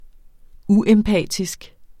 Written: uempatisk

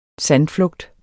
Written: sandflugt

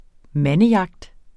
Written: mandejagt

